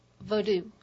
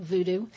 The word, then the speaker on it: Vodou: Vodou, I say. – Vodou. – Which distinguishes it from the touristy Hollywood version, voodoo.